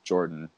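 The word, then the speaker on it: Yordan: And I guess I've learned recently that it is actually pronounced Jordan.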